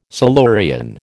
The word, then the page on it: Silurian: American English